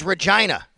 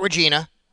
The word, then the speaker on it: Regina: Well, thank you so much to so many of you who have tweeted me at Sal Sports to let me know it is Regina, Saskatchewan, not Regina.